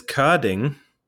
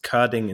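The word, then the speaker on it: Körding: That's why I no longer even tell most people that I technically have two dots on my O, and therefore it is Kording instead of Kording.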